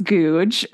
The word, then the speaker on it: Goudge: And by the way, for listening folks who have not encountered her, her last name is spelled G-O-U-D-G-E, and it is pronounced Googe.